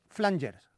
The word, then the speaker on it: Flangers: pronunciado yo el nombre de la banda, los Flangers, pero como tengo aquí a mi Silvio Jiménez, que es una enciclopedia musical andante, me corrige y suponemos que si el nombre de la banda hace referencia a ese pedal de guitarra que utilizaba, por ejemplo, Enrique Sierra de Radio Futura, pues la pronunciación correcta sería los Flangers.